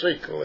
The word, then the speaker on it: sekel: And in the Hebrew, insight is sikel, pronounced sikel, S-E-K-E-L, in the sight or before of God Elohim and man Adam.